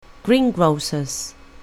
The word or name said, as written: greengrocer's